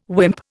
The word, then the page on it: wimp: Indian